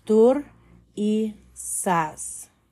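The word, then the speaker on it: Thurisaz: So the phonetic value is th, th. Pronunciation is tur i sas.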